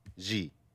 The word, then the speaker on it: Xi: Xi.